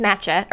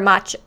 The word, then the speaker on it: matcha: And it's matcha or matcha. I already forgot. Tea. I listened to it on YouTube. – I think it might be matcha.